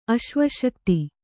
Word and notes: ಆಸವಾಸಕ್ತ: āsavāsakta

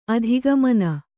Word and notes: ಅಧೋಗಮನೆ: adhōgamane

